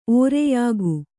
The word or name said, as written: ಒರೆಯುಗಿ